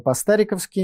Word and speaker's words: по-стариковски: В эфире очередной выпуск программы по-стариковски. Именно так, с ударением на букву «А».